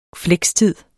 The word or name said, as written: flekstid